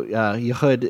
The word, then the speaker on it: Yehud: And this is also, Yehud is pronounced with a hard CH, similar to the letter J in the name Javier, just in case this comes up in discussion.